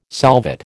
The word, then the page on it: solvate: American English